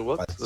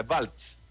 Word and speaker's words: Waltz: Christopher Waltz. No sé. Oye, pero ¿qué? Ah, está mal pronunciado, ya, Waltz.